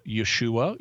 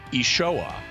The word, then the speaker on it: Yeshua: When I took Aramaic, I learned an Eastern dialect of Aramaic, and in that, Jesus's name is pronounced Yeshua.